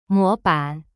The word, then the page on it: 模版: mo ban